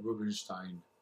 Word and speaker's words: Rubenstein: My family would actually pronounce it Rubenstein. – Rubenstein. But you're the correct pronunciation. Do we want to dox you about the correct pronunciation of our last name? – No, but I know the correct pronunciation of Rubenstein. – Anyway. – It's like Frankenstein.